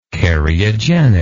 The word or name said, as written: cariogenicity